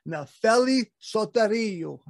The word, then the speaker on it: Nefeli Soteriou: And our guest is Nefeli Soteriou.